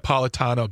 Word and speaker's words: Napolitano: I'm a big, simple Napolitano. Oh, how do you say that? Napolitano, a margarita. – It's pizza Janet guy. Napolitano. Get it right.